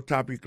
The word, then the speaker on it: topic: Alors, mot topic là, qui signifie sujet, ça veut dire accent tombe sous premier syllabe.